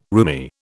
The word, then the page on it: roomy: British English